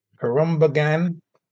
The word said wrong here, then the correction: Khruangbin: Oh, Khruangbin? Khruangbin, yeah.